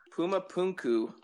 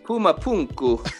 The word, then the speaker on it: Puma Punku: Puma Punku. – I like to hear you say it in your Latin voice, though. More of an accent. – Puma Punku.